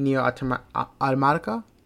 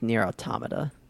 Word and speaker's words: Nier Automata: I know how much you love Nier Automata. – The actual pronunciation is Nier Automata, but you know, Nier Automata.